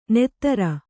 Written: ನೆತ್ತರ

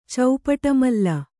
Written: ಚೌಪಟಮಲ್ಲ

